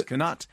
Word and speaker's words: Knut: You pronounce the K and T in Knut, and the D in Mads is pronounced as a T. Someone has been lying to you, Mark. So Knut is Knut, and Mads is Mats.